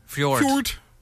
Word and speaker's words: Fjord: Aside from Fjorn. – Fjord. Yeah. – You're going to have to say it like Swedish Chef.